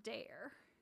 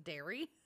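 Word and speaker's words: Daire: But it's D-A-I-R-E. – Dare. I would have pronounced that initially as Dairy. – That's funny. I probably would have too if she hadn't said... She, like, says it in the, the reading, in the text, that it rhymes with care or something.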